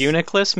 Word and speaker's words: Reuniclus: Did I say that right? Reuniclus. – Reuniclus, maybe? – Reuniclus, yeah, there we go.